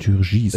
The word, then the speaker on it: Turgis: Anthony Turgis. – Turgis.